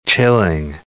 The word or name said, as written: chilling